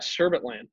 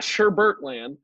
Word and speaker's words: Sherbet Land: Yeah, Sherbet Land. It's not Sherbert Land. Now, I will say this. I have said Sherbert in my life. I definitely have pronounced it that way.